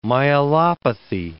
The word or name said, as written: myelopathy